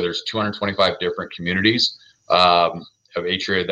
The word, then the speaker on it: Atria: So you mispronounced it. I'm not sharpshooting, but it's Atria Senior Living.